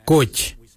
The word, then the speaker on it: Goodj: Karli is spelled G-I-I-L-Y-I, karli. For the traditional stone axe, we say koch, koch. Koch is spelled G-O-O-D-J, koch.